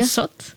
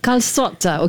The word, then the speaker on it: calçot: How do I pronounce it? – Calçot. – Calçot, okay.